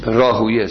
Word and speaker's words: راهویه: راهویه سمته، لیکن خب ما تلفظ بگیم راهبه، به الا مثلا سیبویه، صحیح سیبویه است، خالویه، قولویه، علایه حالن راهویه.